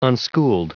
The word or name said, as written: unschooled